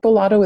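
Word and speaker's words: Beau Lotto: Bolotto is a neuroscientist. He's a UK neuroscientist. His name is spelled B-E-A-U and then Lotto, L-O-T-T-O.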